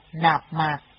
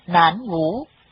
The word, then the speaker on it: Nam Mô: Hạnh là chí thành khẩn thiết, thường niệm Nam Mô, âm đọc là Nạp Mạc. Do chữ Nam Mô, nếu đọc theo giọng quan thoại thông thường, sẽ thành Nản Ngũ, nên ở đây tổ ghi âm bằng tiếng Nạp Mạc, âm quan thoại là Nam Mô, để chỉ cách đọc mô phẩm Nam Mô trong tiếng Phạn.